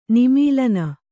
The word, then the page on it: ನಿಮೀಲನ: nimīlana